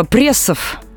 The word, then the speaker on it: пресс: А, на самом деле все несложно, потому что независимо от значения, пресс произносится именно так, с мягким «ри». А словари отмечают, что произношение «пресс» неправильно.